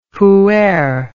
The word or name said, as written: whoeer